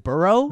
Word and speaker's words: Edinburgh: Isn't it Burrow? – Yeah. – But the spelling is weird. Edinburgh?